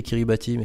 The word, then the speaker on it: Kiribati: Et ensuite, ce pays, donc les îles Kiribati, ça s'écrit Kiribati, mais ça se prononce Kiribati, ont une particularité, c'est qu'elles sont menacées par la montée des eaux due au changement climatique.